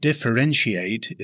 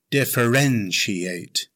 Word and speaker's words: differentiate: Now, the word differentiate is a verb. It has five syllables. Stress the third syllable. It's pronounced differentiate. Differentiate.